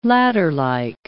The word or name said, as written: ladderlike